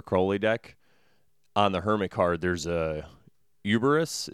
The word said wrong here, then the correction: Cerberus: Is that how you say it? The three-headed dog? – Oh, Cerberus. – Oh, yeah, Cerberus.